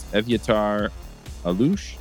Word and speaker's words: Evyatar Alush: We have a special guest this week, and that's Evyatar Alush.